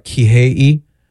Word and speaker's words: Kihei: All right, Kihei.